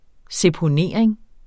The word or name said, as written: seponering